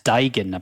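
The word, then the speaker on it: Duigan: And thereby to John Dugan. – Yeah, it's pronounced Dugan, apparently.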